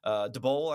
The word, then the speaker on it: Daboll: Daboll, I think, is actually how you say it, according to the press conference.